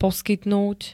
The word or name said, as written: poskytnúť